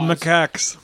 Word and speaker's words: macaques: The monkeys, I can't pronounce this, macaws. – They're called macaques.